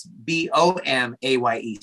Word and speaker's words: Bomaye: So my Instagram is Bomaye, and the B and the way you spell it is weird. It's B O M A Y E. So B O M A Y E. Bomaye is, is kind of how they pronounce it.